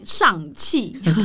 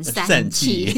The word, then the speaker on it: 尚氣: 呃，去年九月的时候，有一部漫威的最新的超级英雄电影《上气》，那这要好好念，很不小心就会念成“ 散气”。